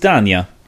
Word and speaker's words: Stana: Is it Stana, Stanya? – Mm. – I'm just going to say. – I always call her Stanya. – It is Stanya.